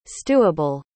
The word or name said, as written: stewable